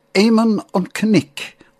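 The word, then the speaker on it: Éamonn an Chnoic: The Irish pronunciation is Eamon an Cnic or Ned of the Hills, but they've got lovely vocal.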